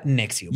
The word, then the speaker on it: NXIVM: Nexi, uh, um, Se escribe N-X-I-V-M. Ah. Voy a llegar a por qué se, por se llama así. – Por mamar. – Pero se pronuncia Nexium.